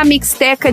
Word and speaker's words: Mixteca: Pois é, e se escreve Misteca com X, Mixteca, que deve ter alguma coisa a ver com tipo México e Azteca.